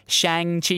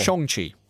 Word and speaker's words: Shang-Chi: who, it turns out, my very white boy pronunciation, Shang-Chi, it's Shang. – Okay. – Shang-Chi.